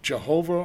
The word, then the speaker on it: Jehovah: What is Jehovah or Yahweh, depending on how you want to pronounce it with the vowel points, what does that mean?